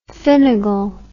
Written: finnagel

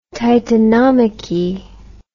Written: titanomachy

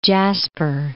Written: Jasper National Park

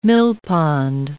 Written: millpond

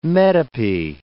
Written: metope

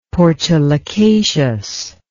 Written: portulacaceous

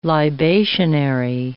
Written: libationary

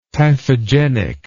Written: typhogenic

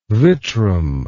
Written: vitrum

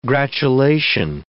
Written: gratulation

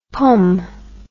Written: pomme

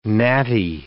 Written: gnatty

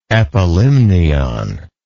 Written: epilimnial

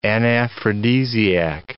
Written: anaphrodisiac

